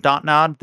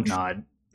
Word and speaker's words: Dontnod: Is it Dontnod or Dontnod? Dontnod, I guess, but then they like they pronounce or how they format the word, and it's literally the English words don't nod.